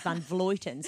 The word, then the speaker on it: Vleuten: Van Vleuten, I say, but Rose is sitting next to me and I know that she says Van Vleuten, so I should say it properly, Van Vleuten, with Chloe Hosking in third.